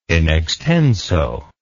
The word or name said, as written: in extenso